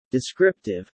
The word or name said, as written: descriptive